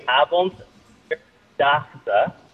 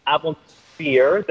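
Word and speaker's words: Avondvierdaagse: So it is, I can say slowly, Avondergotse. – Avond, what's the middle? – Avondergotse, the number 4, fear.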